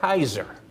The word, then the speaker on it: Caesar: And if you look at the proper, I've been corrected on this, those C's are pronounced with a K. I heard somebody on a podcast someplace, and they were talking about history. They were talking about Cicero. He blurted out Cicero. Like, oh, that's the proper name. Just like Caesar, think of the modern word today called Kaiser.